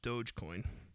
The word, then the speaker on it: Dogecoin: And I learned today that it's pronounced Dogecoin, which I'm kind of upset about, so I'm going to keep calling it Dogcoin. – Yeah, it's because in my head, that reads doggy.